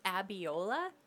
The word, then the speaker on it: Abiola: What did you say her name was? – Abiola. A-B-I-O-L-A.